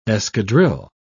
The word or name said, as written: escadrille